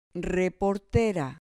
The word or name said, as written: reportera